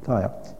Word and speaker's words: Gaia: But, how do you say it? Gaia? Gaia, yeah.